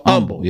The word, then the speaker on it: Humble: Humble. How do Houstonians say it? Do you say it with the H or not? – No H. – So Humble.